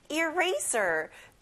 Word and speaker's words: eraser: Ray에 크게 말해보세요. Eraser.